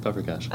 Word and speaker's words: paprikash: That's how you say it, paprikash.